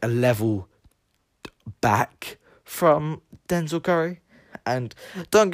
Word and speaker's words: Denzel Curry: I think he's quite underground, kind of like how Dizelle, or was it, how do you say his name again? Dizelle Curry, Denzel, that's it, Denzel Curry.